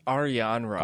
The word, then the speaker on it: Arianrhod: Yeah, so it's Arianrhod. – Arianrhod. – It's a Celtic name.